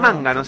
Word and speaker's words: Mangano: Mangano, sí, porque sería así como el acento en italiano.